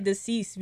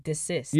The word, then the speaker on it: desist: Desist. I always say deceased because it rhymes with cease. – No, you're dead right. – That's just how my brain works. – No pun intended. You're dead right. – But it's cease and desist.